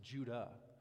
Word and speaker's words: Judah: So Jude is actually probably pronounced Judah. And the reason that we get this wrong is because of something called transliteration, which sounds like a big fancy word.